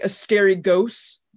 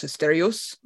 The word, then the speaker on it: Asterios: Asterios, Curse of the Stars, which looks like Asterios, but it's Greek, so it's Asterios, like Euro. You don't. It's not gyro.